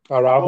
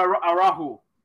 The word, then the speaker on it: Araujo: Araujo. Oh, yeah, Araujo.